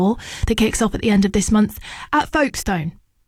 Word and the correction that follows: Folkestone: I was promptly told it is pronounced Folkestone.